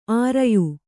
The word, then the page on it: ಆರಯ್ಯು: ārayyu